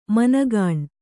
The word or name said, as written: ಮನಗುಣ